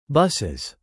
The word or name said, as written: buses